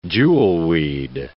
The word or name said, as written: jewelweed